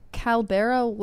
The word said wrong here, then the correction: Caballero: left Disney. – Caballero.